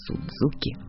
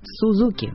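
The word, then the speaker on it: Suzuki: Так что произношение «Сузуки» от лукавого. «Сузуки» – вот так правильно.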